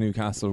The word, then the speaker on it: Newcastle: I'm also fascinated, Gav, by the fact that, like, is it a Longford thing that you pronounce Newcastle where you put the emphasis on the castle? – Yeah, I don't know.